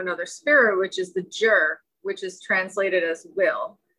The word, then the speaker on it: zhi: How do you spell that one? – Z-H-I. – Oh, okay, yeah. I got that one, yeah. – It doesn't sound like it's spelled.